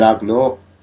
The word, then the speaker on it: Ugnaught: an Ugnaught, am I right? – Sounds right to me then, yeah.